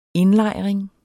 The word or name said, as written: indlejring